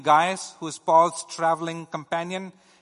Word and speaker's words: Gaius: Latin is Gaius, and English is Gaius. So in case you think that I'm mispronouncing it, you can think of it as the Latin pronunciation of this individual.